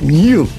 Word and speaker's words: Niall: Niall is pronounced new.